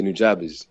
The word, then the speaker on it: Nujabes: Is Nujabes or something like that? – Yeah, Nujabes.